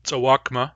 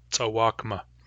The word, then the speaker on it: tsawakma: The word tzawakma, for example, there is one, has a short, long, short pattern. The vowel of the first syllable is short, the vowel of the second syllable is long, the vowel of the third syllable is short.